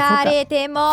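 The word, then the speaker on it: 吹かれても: Questa canzone si chiama, con una pronuncia veramente imbarazzante, Kaze ni Fukataremo. Fukataremo, vabbè, dai. – Fukaretemo. – Fukaretemo, vabbè, insomma.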